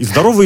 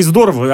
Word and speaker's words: здорово: И здорово, и здорово. Одинаковое ударение можно ставить.